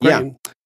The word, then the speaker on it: Crichton: Well, I never saw the, well, I saw the movie on rerun, but it was Michael Crichton. Is that how you pronounce it? Crichton. – Yeah.